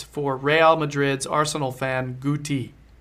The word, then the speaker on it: Guti: It's a hard G. It's Guti. Yes.